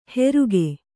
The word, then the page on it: ಹೆರುಗೆ: heruge